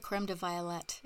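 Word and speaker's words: crème de violette: It's the same. Violette or violet. It doesn't matter.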